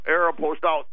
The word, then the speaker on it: Aeropostale: Is that how you pronounce it, Aeropostale? – Yeah, Aeropostale. – I did not know that. I've been saying it wrong the whole time.